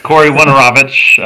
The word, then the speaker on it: Corey Woinarowicz: Corey Woinarowicz.